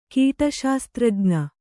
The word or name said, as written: ಕೀಟಶಾಸ್ತ್ರಜ್ಞ